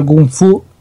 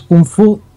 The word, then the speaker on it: Kung Fu: ¿Es Kung Fu? Es lo mismo. La diferencia entre Kung Fu y Kung Fu es si lo pronuncias como cantonés o si lo pronuncias como se hace en mandarín.